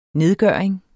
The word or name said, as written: nedgøring